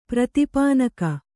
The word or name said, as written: ಪ್ರತಿಪಾನಕ